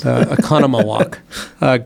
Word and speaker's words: Oconomowoc: Oconomowoc.